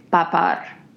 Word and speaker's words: papad: And maybe we should play a sound clip of how it's properly pronounced, but I believe it's something more like papar. Okay. And you kind of turn the second syllable into a rolling UR sound.